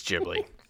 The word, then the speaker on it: Ghibli: For us, like I said, we will be pronouncing it with a soft G, like giraffe. – Yeah. – Yeah. – Even though my whole life I've said Ghibli.